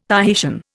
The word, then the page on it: Tahitian: Indian